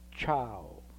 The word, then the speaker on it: ciao: So the letter, the sound ch in front of a, cha, will have to be spelled C-I-A, as in ciao. The sound ch in front of o is spelled C-I-O, as in cioccolato.